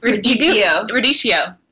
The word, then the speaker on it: radicchio: How do you say that word? Radicchio? – Radicchio. – Okay. – I thought it was radicchio.